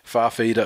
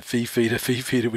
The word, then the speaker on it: Fifita: They would even say it wrong just because it sounds better than Fifita, which just makes you sound like a bunch of cuzzy bros.